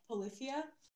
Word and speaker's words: Polyphia: So 10 for me was Polyphia.